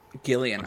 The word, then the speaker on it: Gillian: As well as Jillian Jacobs. She's kind of the narrator of the second movie. – I'm sorry, it's Gillian? – Gillian. – The hard G.